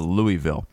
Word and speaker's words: Louisville: Simon Wilson said, not that one, the other one, said kind of like when people say Louisville instead of Louisville. – It's Louisville. – It is Louisville. – That's how it's pronounced.